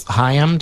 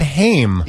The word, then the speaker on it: Haim: Have you listened to Haim? Haim. – It's three girls, sisters. – Oh, I know who that is. – Yeah. – I always said Haim. – Yeah. – Because it's spelled like Corey Haim.